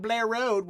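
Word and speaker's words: Bel Air: My favorite Baltimore accent is you go down Blair Road, which is spelled B-E-L space A-I-R Blair Road, you know, like Will Smith, the Fresh Prince of Blair.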